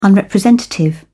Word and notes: unrepresentative: British English

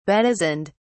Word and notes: bedizened: British English

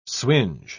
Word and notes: swinge: British English

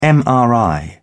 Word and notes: mri: British English

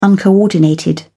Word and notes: uncoordinated: British English

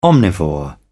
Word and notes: omnivore: British English

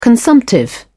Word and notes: consumptive: British English

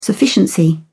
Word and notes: sufficiency: British English

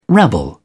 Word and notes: rabble: British English

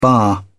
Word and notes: barre: British English

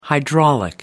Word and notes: hydraulic: American English

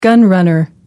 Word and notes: gun runner: American English

